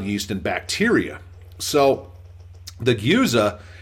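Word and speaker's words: Gueuze: I went to four different websites trying to figure out how to pronounce this. It's a Dutch word. And that is the one that was the most consistent. If Gyuza, if you slow it down.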